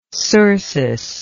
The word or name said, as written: cercis